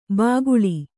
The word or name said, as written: ಬಾಕುಳ